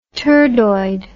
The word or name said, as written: turdoid